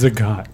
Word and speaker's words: Zagat: It's pronounced Zagat. – It's Zagat. – Is it really? – It no. is. Zagat. – Zagat. That's a character from Street Fighter, isn't it? I don't know. Doesn't matter. – Is it Zagat? – No, it's Zagat.